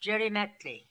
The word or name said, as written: Djerymetly